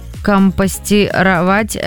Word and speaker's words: компостировать: Вот потом еще у Айгуль недавно стали компостировать все очистки от овощей и фруктов. Отвозим на дачу, там делают компост. Правильно я сказала слово ударение? – Да.